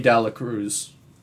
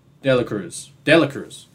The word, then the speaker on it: De La Cruz: And the thing is, people are already comparing him to Ellie De La Cruz. De La Cruz. Said it right the second time.